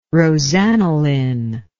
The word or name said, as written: rosaniline